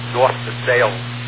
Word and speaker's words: Versailles: It's North Versailles. Yeah, it's not the French pronunciation.